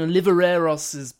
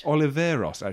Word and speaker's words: Oliveros: It's also Pauline Oliveros' birthday. Have I said it right? – Oliveros, actually.